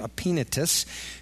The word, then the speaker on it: Epaphroditus: Second half of verse 5 says, Epaphroditus is how you pronounce his name, Epaphroditus.